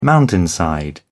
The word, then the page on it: mountainside: British English